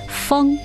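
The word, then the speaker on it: feng (蜂): Bee in Chinese pronunciation, feng, meaning bestow high office. And monkey, pronounced hou, means a marquis.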